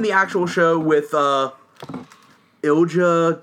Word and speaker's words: Ilja: It's it's it's I L J A. – Dragonov. I think it's Ilya.